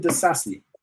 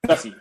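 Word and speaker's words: Disasi: It's definitely the Sassy. – Sassy. Oh, my bad. – Yeah, I definitely said it wrong. But yeah, it's the Sassy.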